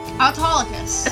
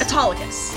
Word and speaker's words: Autolycus: Autolycus. – Autolycus.